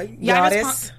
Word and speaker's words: Yris: Y R I S. Yeah, Yadis.